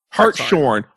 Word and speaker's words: Hartshorn: Sarah Hartsthorn. – Ooh. – Hartsthorn. H-A-R-T-S and then horn with an E on it. – Hartshorn.